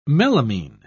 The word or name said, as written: melamine